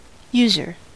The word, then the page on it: user: American English